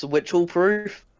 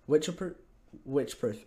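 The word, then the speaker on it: Wycheproof: Isn't it pronounced Witchelproof? – Witchproof. Okay. Yeah, I've heard Witchproof. Pronounced it bad.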